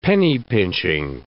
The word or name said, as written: penny-pinching